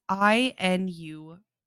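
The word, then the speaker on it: Iñupiat: Then in March of 1933, she was found by a group of, I'm going to butcher this, Inupiat. It's Inupiat. I believe that's a native to this region group of people.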